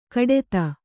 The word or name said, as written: ಘಟ್ಟಿತ